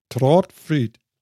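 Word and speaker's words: Trådfri: Ähm, also, wenn ich das richtig verstanden habe, spricht sich das Trotfree aus? – Wie hast äh, du das ähm, jetzt rausgekriegt? Mit O. Trotfried. – Ja, genau. – Okay. – Äh, welches ähnlich wie das deutsche O gesprochen wird.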